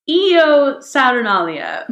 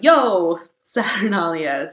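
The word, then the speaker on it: Io Saturnalia: So during this time, you would exclaim, Eo Saturnalia, or if you want to use the Latin pronunciation, it would be more of a Yo Saturnalia.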